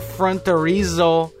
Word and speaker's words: Fronterizo: CD is called Sir Fronterizo. It rhymes with chorizo, I guess.